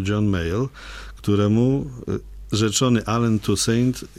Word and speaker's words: Toussaint: Toussaint. – Toussaint mówią, tak jak Toussaint, napisane jest.